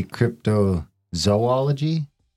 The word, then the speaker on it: cryptozoology: How do you pronounce cryptozoology? Because the way I say it like that, apparently that's wrong. – We've been told that's wrong and it should be cryptozoology. – Cryptozoology. – Three O's.